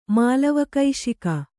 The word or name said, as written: ಮಾಲವಕೈಶಿಕ